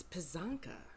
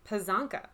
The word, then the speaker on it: Pysanka: It's Pysanka. – Pysanka, okay. – Pysanka. – It's, when it's in English, when it's transliterated into the Roman alphabet, it's P-Y-S-A-N-K-Y, but it's Pysanka.